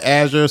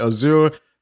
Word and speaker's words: Azure: So some people call it Azure, some people call it Azure. Not sure how to what's the correct pronunciation, but I call it Azure.